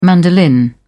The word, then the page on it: mandolin: British English